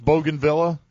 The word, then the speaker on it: bougainvillea: Is that how it's pronounced, bougainvillea? – That's how I pronounce it.